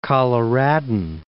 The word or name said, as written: Coloradan